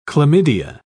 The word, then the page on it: chlamydia: British English